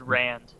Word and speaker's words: Rand: Is it Rand or? – It's Rand. R-A-N-D. – Because there's two. There's a father and son. There's also Ron is the dad.